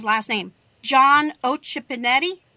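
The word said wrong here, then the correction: Occhipinti: Did I say it right? – John Acapinti. – Close enough. Yeah. John Acapinti.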